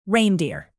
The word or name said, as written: reindeer